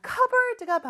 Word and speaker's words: cupboard: 자, cupboard가 바로 그릇이나 컵들을 넣는 찬장을 뜻한다고 했죠. 근데 발음은 cupboard, P 발음 안 해요.